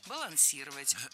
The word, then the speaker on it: балансировать: Здесь неправильное ударение. Переставляем его на третий слог и получаем правильный вариант «балансировать».